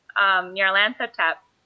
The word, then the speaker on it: Nyarlathotep: Meanwhile, so she sort of stumbles upon, you know, this weird, you know, sort of Lovecraftian culture stuff with Nyarlathotep.